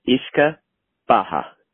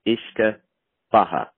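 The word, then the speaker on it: uisce beatha: So it's pronounced Ishka Baha. Ishka Baha.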